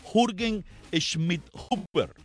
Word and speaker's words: Jürgen Schmidhuber: Se llama, creo que se pronuncia así en alemán, Jürgen Schmidhuber. Así se, bueno, se escribe así, J, no, se llama así, Jürgen Schmidhuber.